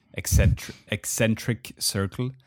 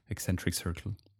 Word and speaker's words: Eccentric Circle: And we haven't mentioned the name of his label, Eccentric Circle, is how you pronounce it, Eccentric Circle.